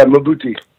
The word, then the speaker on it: Mbuti: Am I saying that right? Is the Mbuti? – Yeah, Mbuti.